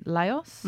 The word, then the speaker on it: Laos: It's called Our Journey Through Vietnam, Cambodia and Laos. Yes, I said that right. Woo-hoo. – Or Laos, I think they say, but we say Laos here in Australia.